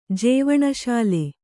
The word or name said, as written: ಜೀವನಸಾಲೆ